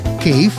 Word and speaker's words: кайф: Правда, по пути слово «кайф» несколько видоизменило свое звучание, поскольку в оригинале на арабском языке оно произносится как «кейф» или «кейф».